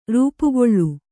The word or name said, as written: ರೂಪುಗೊಳ್ಳು